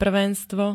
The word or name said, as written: prvenstvo